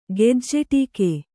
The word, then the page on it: ಗೆಜ್ಜೆಟೀಕೆ: kannada